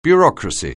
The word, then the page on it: bureaucracy: anglais